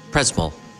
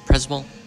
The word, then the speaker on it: Przemyśl: In could you pronounce exactly where we? It's Przemyśl. Przemyśl. This town where we are. – This Przemyśl. – Przemyśl. I'm sorry. Przemyśl. I pronounce it completely different.